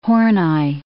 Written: Horney

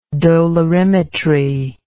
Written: dolorimetry

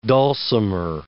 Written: dulcimer

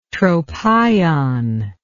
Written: tropaion

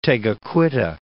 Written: Tegakouita

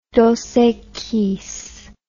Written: dosequis

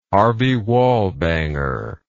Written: harvey wallbanger

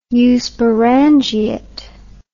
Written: eusporangiate

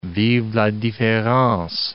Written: vive la difference

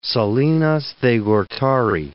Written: Salinas de Gortari

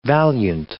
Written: valiant

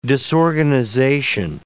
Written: disorganization

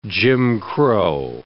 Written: Jim Crow

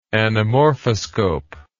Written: anamorphoscope

